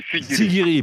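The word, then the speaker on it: Siguiri: À Sirigui. – Sigiri, pardon, j'ai écorché le nom de votre ville, Sigiri.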